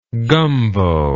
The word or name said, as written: gombo